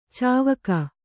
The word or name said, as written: ಚಿವಿಕೆ